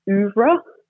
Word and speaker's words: oeuvre: We have Alexis Wright reading through her extraordinary oeuvre. Is that the right way to pronounce that word? I always look, it sounds right. That. It sounds right in my head, so I'm going to defer to you.